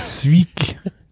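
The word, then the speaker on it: Winterswijk: Winterswick. – Und das J wird gar nicht ausgesprochen, oder was? Hab ich doch. Win Winter Sweek. Sweek, Sweek.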